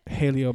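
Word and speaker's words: Jaleo: Haleo, because... – Haleo. – Haleo. – Okay. – Yes. – Haleo. – Not J-Leo. Haleo. Because the J in Spanish is pronounced an H.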